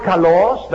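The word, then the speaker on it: kalos (καλός): You see the word kalos? That's the way you pronounce this word.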